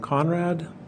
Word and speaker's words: Konrad: Uh, my name is Adam Konrad, spelled A D A M K O N R A D.